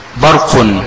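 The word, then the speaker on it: برقٌ: انا عندي برق، دي بتتنطق ازاي؟ هي مكتوبه ب باء راء قاف عليها ضمتين من فوق، لكن في النطق بتتنطق ازاي؟ نطق باء راء قاف مضمومه نون ساكنه، صح؟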